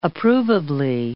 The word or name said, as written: approvably